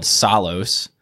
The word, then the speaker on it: Psallos: Anyway, so this music group I started listening to this year, they're called Psalos. And that's, yes, it's spelled P-S-A-L-L-O-S. – Like pterodactyl or Psalms. – Like, yes, it has a silent P like pterodactyl or the Psalms.